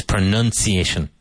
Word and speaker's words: pronunciation: Now, one of the great ironies of the English language and of English teaching is that I would say 9 out of 10 native English speakers say pronunciation. You have to improve your pronunciation. It's pronunciation. Nun, like monja.